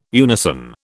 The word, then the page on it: unison: British English